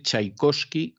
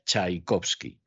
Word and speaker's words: Tchaikovsky: Pues muy sencillo, a que eran libros que se traducían del francés y en francés la Che se lee sh y sin embargo para pronunciar la Che colocan una T delante. Y entonces el francés que ve la T y luego la Che pronuncia Tchaikovsky.